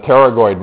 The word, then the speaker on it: pterygoid: Funny spelling. The P is silent. So this is the pterygoid muscles.